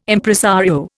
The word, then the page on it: Impresario: Indian